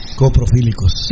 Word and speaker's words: coprofílicos: Un saludo a los coprofílicos. – Coprofílicos. – Ya lo dije bien, coprofílicos.